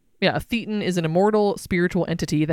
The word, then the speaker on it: thetan: They believe that the human individual is actually a thetan. Am I saying that right? – I think so.